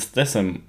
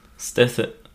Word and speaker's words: Statham: Naja, aber es ist nicht Stat und dann Ham. Also TH, aber Ja, aber der wird doch nicht Statham ausgesprochen. Jason Statham. – Du sagst nicht Jason. Ah, okay. – Nee, das, das hat nichts mit, mit Schinken zu tun.